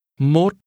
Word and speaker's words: mốt: Mốt. Mốt. 41. – The number 1 in combination is pronounced with a rising tone.